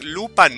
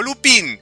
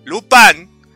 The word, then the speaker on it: Lupin: Lupin, no Lupin, Lupin, Eso lo pronuncian así en Japón, Lupin.